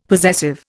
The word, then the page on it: possessive: Indian